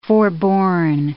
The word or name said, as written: forborne